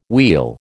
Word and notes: wheal: American English

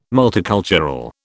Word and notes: multicultural: British English

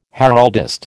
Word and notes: heraldist: American English

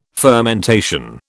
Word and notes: fermentation: British English

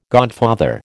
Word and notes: godfather: American English

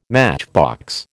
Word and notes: matchbox: American English

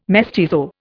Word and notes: mestizo: Indian

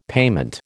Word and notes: payment: American English

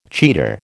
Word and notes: cheater: American English

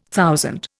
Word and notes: thousand: Indian

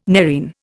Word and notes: nerine: Indian

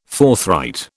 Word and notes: forthright: British English

